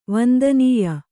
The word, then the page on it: ವಂದನೀಯೆ: vandanīye; kannada